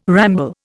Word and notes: ramble: Indian

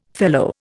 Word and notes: fellow: Indian

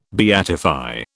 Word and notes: beatify: British English

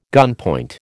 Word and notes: gunpoint: American English

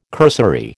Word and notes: cursory: American English

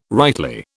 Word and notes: rightly: British English

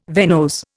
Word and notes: venose: Indian

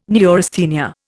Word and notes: neurasthenia: Indian

